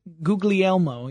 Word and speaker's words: Guglielmo: Guglielmo? – Guglielmo, yeah, I would say.